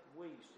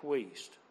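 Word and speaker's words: Wuest: Called the New Testament, and it's by Kenneth Wiest. It's spelled W W-U-E-S-T. W-U-E-S-T. Kenneth Wiest.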